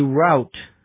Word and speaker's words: route: A route, R-O-U-T-E, or a route, it can be pronounced either way, is usually a road or a path, a way to get somewhere.